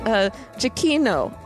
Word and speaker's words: Giacchino: Giacchino.